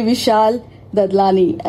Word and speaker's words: Vishal Dadlani: And this is one and only Vishal Dadlani.